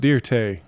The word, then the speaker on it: Dirt: It's pronounced Dirte.